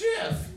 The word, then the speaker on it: GIF: The creator of Jif says it's pronounced Jif.